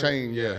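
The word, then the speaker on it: Achane: Devin, I think Achanye is how you say his last name. Is that right? – It's like a Shane or something? – Shane. He's really good.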